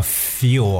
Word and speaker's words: fuel: 而且说完了 face，还有 drive，我们今天看一下最后一个常见的一个词，这个词叫 feel 啊。但读的时候特别注意一下它的发音啊，feel 啊，有个舌侧音在后边，它的拼写是 f u e l feel。